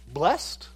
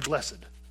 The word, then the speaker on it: blessed: Is it pronounced blessed or blessed? At first I thought it was dependent on the part of speech, right? If it's a verb, then you say blessed.